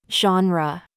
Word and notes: genre: American English

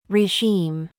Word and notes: genre: American English

